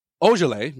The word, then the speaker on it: Ojeleye: And good old Semi Ojeleye. Oh no, I'm sorry. It's Ojeleye because he's not French, right?